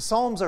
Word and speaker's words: psalm: The psalms are songs, right? That's what psalm means. It's spelled with a P.